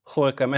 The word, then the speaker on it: Gorkum: Eh, of hoe, hoe, hoe, spreek je het uit? Uh, Gorkum. En waar is het?